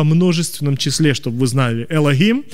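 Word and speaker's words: Элохим (אֱלֹהִים): Но это слово Боже звучит как Элохим. Кто-нибудь слышал такое слово? Мы по-русски говорим Элохим, просто хэ произносим. Во многих языках хэ просто выдыхается. Элохим.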